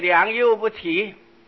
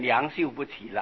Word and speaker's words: 良莠不齐: 我们平常说这个良莠不齐，那个幼字跟秀字不是很相望吗？那么不懂的没有注意的都念成良秀不齐了，这个都是会闹出笑话的事情。